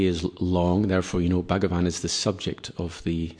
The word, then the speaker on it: Bhagavan: Bhagavan, when it's a long A at the end, Bhagavan, it's the object of the sentence. And if you want to say, O Lord, you would say Bhagavan. The last A would be short.